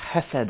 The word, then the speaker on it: chesed: That wonderful word in the Old Testament, chesed, I think it's pronounced.